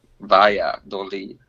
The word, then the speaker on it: Valladolid: Valladolid.